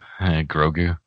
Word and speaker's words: Grogu: How do you say it? – Grogu. – Grogu. – It's probably Gogru, and I keep saying it wrong. I'm correcting you the wrong way. I'm going to look. – Grogu. G-R-O-G-U. Yeah. – Yeah. G-R-O-G-U.